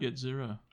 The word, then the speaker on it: Yetzira: Yetzira. – I said it right. – Yeah, you did.